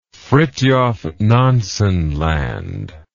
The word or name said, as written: Fridtjof Nansen Land